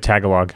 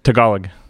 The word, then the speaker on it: Tagalog: Boy, was I saying that wrong. – You said Tagalog. – Yeah, that's... – Yeah, you're a terrible person. – Again? – Tagalog.